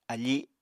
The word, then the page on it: afi: Apatani